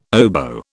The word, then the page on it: oboe: British English